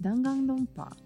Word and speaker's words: Danganronpa: Danganronpa? Yeah, I think that's how you say it. – Danganronpa.